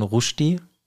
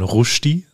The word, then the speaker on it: Rushdie: Ja, deswegen haben wir ja heute mal ein Buch von Salman Rushdie.